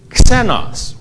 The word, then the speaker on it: xenos: Now the New Testament word for alien or stranger is a Greek word.And it's the word xenos. Whoa. Xenos. We could spell it xi, spelled with a xi, but we could spell it X-E-N-O-S.